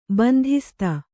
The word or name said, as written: ಬಂಧಿಸ್ತ